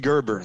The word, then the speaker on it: Gerber: I said the hard G. Gerber.